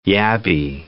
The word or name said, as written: yabby